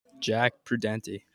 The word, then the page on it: Jack Prudente: male